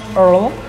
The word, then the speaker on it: Earl: Yeah, so you should pronounce like this. Earl.